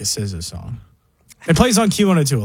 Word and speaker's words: SZA: Is this how you pronounce it? Za? I'm doing research. – SZA. – Hey, SZA. All right.